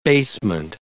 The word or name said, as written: basement